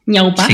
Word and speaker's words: Ñaupaj: Quería preguntarle, tu futuro en la espalda, o sea, viene de la palabra del quechua, o sea, escuché mencionar en entrevistas de Ñaupaj, pero está pronunciando bien. Ñaupaj. Sí. Sí.